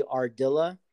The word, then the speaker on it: Ardila: It's going to be Ardila, A-R-D-I-L-A, my last name, Capital.